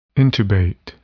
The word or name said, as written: intubate